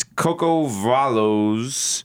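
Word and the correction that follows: Cuco Valoy: Bale El Cal. Listen, it's like I can't pronounce this shit. – Some Spanish shit? – Okay, you can pronounce it. – Oh, shit, it's Cuco Baloy.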